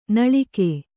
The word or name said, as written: ನಾಲಿಗೆ